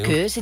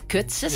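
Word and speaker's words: Koze: Ja, DJ Keuze, DJ Kutze, moet je eigenlijk zeggen, maar dat vind ik zo ongezellig.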